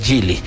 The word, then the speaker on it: Gili: Es con G. gili.taomotor. Así se escribe Gili.